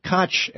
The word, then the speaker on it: Koch: And Koch groups, as Koch, actually, is how it's usually pronounced, the notorious billionaire brothers, raised more in 2012, they raised more than all the presidential candidates spent in the 2000 election.